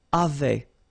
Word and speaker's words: avaient: And then you saw probably that we've got also here A-V-A-I-E-N-T, but then you pronounce it avait.